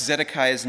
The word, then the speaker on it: Zedekiah: And so let me pronounce Zedekiah's name a little bit closer to a Hebrew-style pronunciation. Zedekiah.